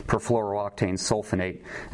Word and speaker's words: perfluorooctane sulfonate: The pronunciation is perfluorooctane sulfonate.